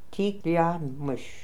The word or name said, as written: Tikliamish